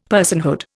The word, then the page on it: personhood: Indian